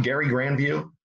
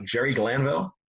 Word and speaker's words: Jerry Glanville: You know why I'm calling him Gary Granview and not Jerry Glanville? – That was how the Three River Stadium PA announcer pronounced his name back when he first started in it was 87, 86.